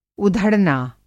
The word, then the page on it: उधड़ना: ادھڑنا